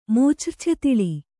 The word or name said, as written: ಮೂಚ್ರ್ಛೆತಿಳಿ